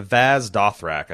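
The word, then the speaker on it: Vaes Dothrak: I thought I had the pronunciation in my head, but it's Vaz Dothrak, I think is how you pronounce it.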